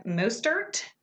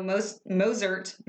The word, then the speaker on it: Mostert: I think the T is silent, isn't it? – Mostert? – Maybe. – M-O-S-T-E-R-T. Mostert. Mostert. Whatever.